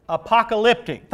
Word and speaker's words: apocalyptic: The correct term would be apocryphic. How do you say that? Apocalyptic. Thank you. There you go.